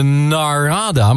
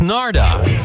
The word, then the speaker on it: Narada: Iedereen, je schrijft namelijk Narada, maar je zegt Narda.